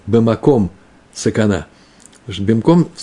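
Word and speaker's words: бимком (במקום): Э, только за это произносит бимаком. Вообще по закону нужно говорить бимком сакана.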